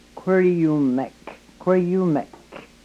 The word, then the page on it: kwryumek: yurok